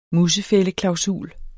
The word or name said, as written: musefældeklausul